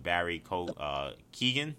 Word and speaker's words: Barry Keoghan: We got our first look at an on-screen interaction between Robert Pattinson's Batman and Barry Keoghan. Is that how you pronounce his name? – I'm always... Yes.